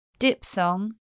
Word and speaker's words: diphthong: PH spelling before th is pronounced per in 2 words. Diphthong, diphtheria.